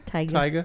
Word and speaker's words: Tyga: Do you know how to pronounce the name? – Tyga? – This is definitely Tyga. Tyga. – I think so. T-Y-G-A.